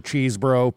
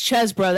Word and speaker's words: Chesbro: Okay, let's talk about Ken Cheesebro, which, by the way, I know on cable news, they've moved away from the Cheesebro pronunciation. What are they saying now? Chesbro? – It's Chesbro. That's actually Chesbro. How you pronounce it.